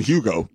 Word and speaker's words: Hugo: You said he was worse than Hugo. – You know? – But you're doing, you're a texter. – The H is silent.